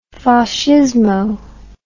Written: fascismo

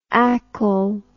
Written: acol

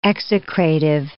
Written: execrative

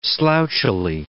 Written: slouchily

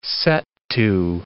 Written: set-to

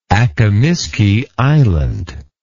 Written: Akimiski Island

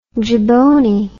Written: jiboney

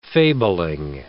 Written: fabling

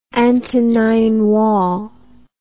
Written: Antonine Wall

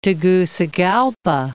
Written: Tegucigalpa

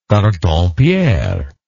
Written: carton pierre

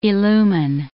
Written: illumine

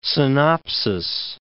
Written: synopsis

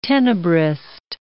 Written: tenebrist

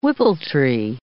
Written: whippletree

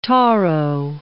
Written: taro

